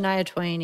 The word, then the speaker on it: Shania Twain: Shania Twain.